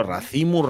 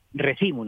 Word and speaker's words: recimo: Claro, racimo, recimo, claro, claro, claro. No, no, eh, eh aquí se dice recimo, no se dice racimo.